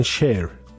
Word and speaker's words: شیر: خب آبش میدونی که این تلفظش در دری و زبان‌های فارسی دیگه میگن شیر، یه جور شعر، مثلا شیر رو میگن شعر.